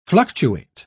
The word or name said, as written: fluctuate